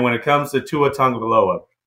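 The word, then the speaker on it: Tagovailoa: yeah, I did say it right. So look, I said it right. Tungvaloa.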